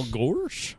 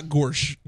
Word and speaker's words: Gorsuch: Is how do you pronounce his last name? – Yeah, somebody clarify this. – Gorsuch. – Yeah. – Gorsuch? – Gorsuch. – Okay. Gorsuch. – Gorsuch, okay. – All right. – Yeah, not Gorsh.